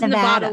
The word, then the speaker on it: Nevada: Like, Jensen says Nevada. Like, I say Nevada. Yeah, and she's like, Nevada.